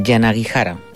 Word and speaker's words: Yanagihara: Hoy arrancamos con un libro que se llama Tan poca vida, de Hanya Yanagihara, un nombre un poquito hawaiano y un poquito complicado de pronunciar. Yanagihara sería la pronunciación correcta.